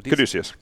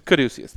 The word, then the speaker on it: Caduceus: Caduceus. – Caduceus.